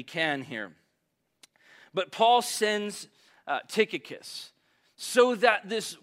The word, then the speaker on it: Tychicus: The pronunciation of this name is a disagreement. I heard four different versions this morning as I was trying to, like, how do you say this name? I landed on Tychicus, and Vinny came and asked me, hey, what did you land on?